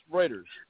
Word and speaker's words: Reuters: She's recently been on the news in Reuters. And how do you pronounce it? Reuters? Reuters. – Reuters. – News, right? Yeah, Reuters.